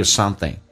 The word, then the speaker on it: something: 我得 Cause I gotta tell you something. You 可以不被强调，可以说成 you, 也可以说成也，发成一个也这个发音。 Cause I gotta tell you something. Something 这个单词，那有很多种方式来说。第一个是 ing 形式，可以说成 in, 前鼻音的形式 something。那么一般说的比较多，说成 something, 发成 n 这个发音，甚至不咬舌可以说成 something 都可以，变成 Cause I gotta tell you something.